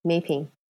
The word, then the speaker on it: Mei Phing: Yeah, Mei Phing.